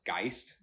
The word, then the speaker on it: Geist: We're talking about his idea of spirit, which is, I think it's pronounced Geist. That was the original German, I believe.